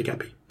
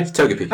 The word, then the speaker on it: Togepi: Togepi.